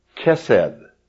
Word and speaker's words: Chesed: It's usually C-H-E-S-E-D. Kesed.